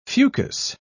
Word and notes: fucus: British English

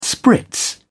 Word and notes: spritz: British English

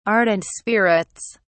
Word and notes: ardent spirits: British English

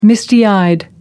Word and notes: misty eyed: American English

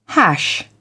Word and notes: hash: British English

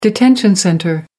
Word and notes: detention centre: American English

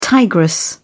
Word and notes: tigress: British English

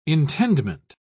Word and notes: intendment: British English

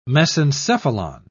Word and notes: mesencephalon: British English

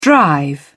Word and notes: drive: British English

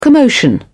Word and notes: commotion: British English

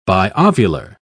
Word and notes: biovular: British English

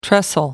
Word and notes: trestle: American English

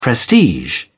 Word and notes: prestige: British English

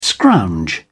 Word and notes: scrounge: British English

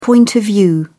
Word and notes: point of view: British English

